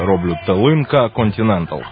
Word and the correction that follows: Континенталь: правильно? – «Талинка Континенталь», «Талинка» – це закарпатський інструмент.